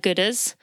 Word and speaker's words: Goodr: Last year I mentioned that I have Oakleys and Gooders. So Gooder is spelled G-O-O-D-R.